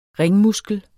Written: ringmuskel

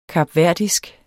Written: kapverdisk

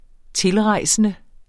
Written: TILREJSENDE

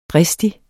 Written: dristig

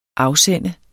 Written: AFSEND

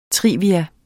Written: trivia

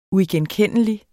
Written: uigenkendelig

